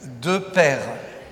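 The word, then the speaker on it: De Pere: De Père.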